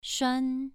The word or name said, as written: shuan2